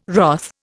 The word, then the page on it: wrath: Indian